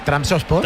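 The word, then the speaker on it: Trabzonspor: Pero ¿cómo se dice? ¿cómo es? – Es Transport.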